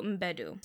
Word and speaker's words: Mbedu: There's Viola Davis as Naniska, and then there's Nawi is played by Tuso Mbedu. – So they pronounce the M, okay.